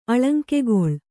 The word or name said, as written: ಅಳಂಕೆಗೊಳ್